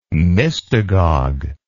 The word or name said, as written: mystagogue